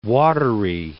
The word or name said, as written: Wateree